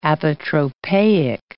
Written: apotropaic

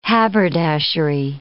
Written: haberdashery

